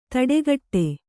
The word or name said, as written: ತಡೆಗಟ್ಟು